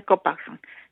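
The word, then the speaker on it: Copaxone: Se escribe copaxón, pero es Copaxon.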